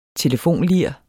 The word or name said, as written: telefonlir